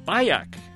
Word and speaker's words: Bayak: Aleshu Bayak, rhymes with kayak, is Spanish language producer.